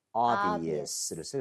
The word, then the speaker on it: obvious: 바로 obvious를 씁니다. 그런데, 여러분, 이 b 있죠? – 네. – 너무 세게 내려고 할 필요 없어요. 거의 발음이 안 된다고 봐도 돼요. 왜냐하면 그 바로 뒤에 있는 v가 더 영향을 받으면서 끌고 가요.